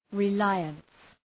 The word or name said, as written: reliance